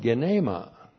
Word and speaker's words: genema: is the accusative plural of genema. Looks like that in the Greek, G-E-N, long E-M-A, genema.